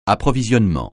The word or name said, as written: approvisionnement